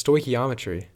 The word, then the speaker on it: stoichiometry: It's stoichiometry.